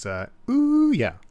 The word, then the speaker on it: Ouya: Even though the Ouya doesn't have the most popular game delivery platform in the entire world on it. But in any case... – I'm sorry, but you mispronounced it. It's Ouya.